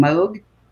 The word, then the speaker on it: Moog: But the fact is, he pronounced his last name Moog.